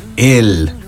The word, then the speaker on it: Elle: Estamos de regreso aquí en Estudio B y hoy nos acompaña la banda L. L. Se escribe E-L-L-E, pero se pronuncia L.